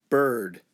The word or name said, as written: bird